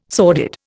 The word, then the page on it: sordid: Indian